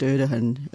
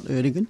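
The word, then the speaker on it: Erdoğan: and I think it's pronounced Erdogan, not Erdogan, was starting to interfere with the sort of secular...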